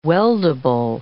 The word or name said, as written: weldable